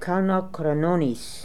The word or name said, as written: Kalnokranonis